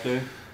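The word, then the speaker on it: Cluj: När ni, vad blev det, det var, vad heter det, Klysch, eller jag vet inte om jag uttalar det, Klöj. – Ja, Klöj.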